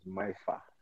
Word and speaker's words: Mifa: And he's none other than, he said the pronunciation of his name is Mifa.